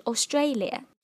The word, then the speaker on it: Australia: 大家注意啊，澳大利亚这个国家它的单词的发音是 Australia， OK， Australia 而不是 Australia。